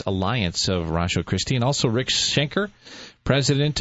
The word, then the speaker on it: Ratiochristi: That's pronounced Rasho Christie, but it's spelled R-A-T-I-O-C-H-R-I-S-T-I. Rasho Christie.